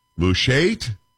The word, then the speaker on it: Mushait: I don't know how to say this, M-U-S-H-A-I-T, Mushait? Can we say, we're going to go with that? Paul, are we good with that? You're not done with that, are we? – Say it the way you need to say it. – Mushait? – Yes. – Yes, that's good. That's good to, you get arid if you say it like that.